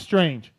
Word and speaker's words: Strang: The goggle of choice of Michael Lessie, Kyle Chisholm, Ryan Sipes, and Josh Strange. Josh Strang. I got an email about this. Wygant bugs me about this, and you spoke to somebody who was also saying I was saying it wrong.